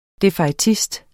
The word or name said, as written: defaitist